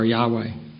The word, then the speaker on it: Yahweh: When we have in our Old Testament the word Lord in all capital letters, that represents God's personal name, Jehovah or Yahweh. For my generation, I'm familiar with Jehovah and I prefer that. The scholarly consensus seems to be that the correct pronunciation of the word should be Yahweh.